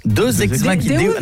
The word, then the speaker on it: deus ex machina: Deus ex machina. – Ah, parce que deux, j'aurais dit que c'était deux ex que t'appelles machine. Tu vois, ça se prononce deus ex machina.